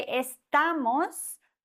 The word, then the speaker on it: estamos: Bei estamos haben wir ein Wort, das auf S endet. Und bei Wörtern, die auf Vokal oder auf N oder auf S enden, wird die vorletzte Silbe betont. Das heißt, bei diesem Wort, das endet auf S, wird die vorletzte Silbe betont. Estamos.